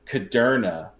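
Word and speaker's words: Kudrna: And he goes, it's Ben Caderna, like Moderna.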